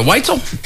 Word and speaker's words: Weitzel: Is it Weitzel? – Weitzel. – Weitzel, I'm pretty sure. – I think it's Weitzel. – We spell it different. – Yeah. – Oh, we do? – W-E-I-T-Z-E-L. Weitzel. – So how is this one spelled? – W-H-I-T-Z-E-L. – Oh, Weitzel. – W-S-E-L.